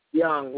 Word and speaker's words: Young: Young, YOUNG.